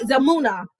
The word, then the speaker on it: Zalmunna: Zalmunna. That's how you pronounce it. Zalmunna.